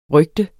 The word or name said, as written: rygte